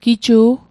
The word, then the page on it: giju': Mi'gmaq